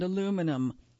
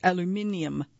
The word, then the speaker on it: aluminium: But he did so as he explained that this component and that component are made of magnesium and carbon fiber and aluminum. He pronounced it aluminium, not aluminum, which I respected as a Brit myself.